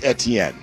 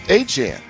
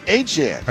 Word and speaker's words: Etienne: I'm going to try like hell to call new Saints running back this year Travis Etienne, because he says it's not pronounced Etienne. He says it's pronounced Etienne. Okay, then let's pronounce it Etienne.